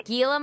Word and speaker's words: Gila: A Gila monster? Is it G-I-L-A? – Yes. Don't you pronounce it like with an H?